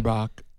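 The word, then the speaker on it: Merbok: But you had the storm's name, if I'm pronouncing it correctly, was Mirbok, I think. – Mirbok. – Yep, Typhoon Mirbok that...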